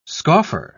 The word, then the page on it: scoffer: British English